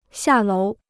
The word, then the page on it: 下楼: xià lóu